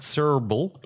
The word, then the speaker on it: Srbely: Okay, so Matt Srbely. The Y is silent.